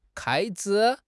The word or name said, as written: 开支